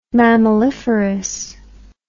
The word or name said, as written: mammaliferous